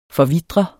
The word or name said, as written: forvitre